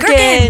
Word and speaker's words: gherkins: They're called gherkins. gherkins. – They're called gherkins. That's how you say it.